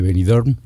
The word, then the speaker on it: Benidorm: ¿Sabes lo bueno que se te ha quedado de Benidorm? Que nadie pronuncia la M como tú. – Claro, claro. – Aquí Benidorm. En España ni Dios pronuncia Benidorm como tú, esa M final.